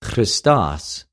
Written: Χριστος

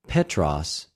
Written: Πετρος

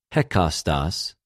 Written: ἑκαστος

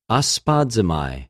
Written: ἀσπαζομαι

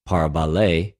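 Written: παραβολή

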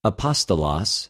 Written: ἀποστολος

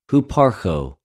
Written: ὑπαρχω

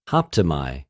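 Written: ἄρχομαι

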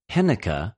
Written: ἑνεκα

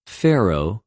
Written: φερω

